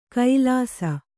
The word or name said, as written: ಕೈಲಾಸ